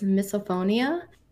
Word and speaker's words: misophonia: So, one of the things that was talked about in the thread was misophonia, and I'm pretty sure I pronounced that right.